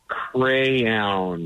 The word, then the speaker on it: crayon: That all right, now say it the way you know it needs to be pronounced. – Crayon. I'm even trying, I can't do it. – We're laughing at you. – Crayon? – No, we are. – That's how it's said? – Yes, it's crayon. – Crayon. – It's crayon. I've been saying that wrong for years.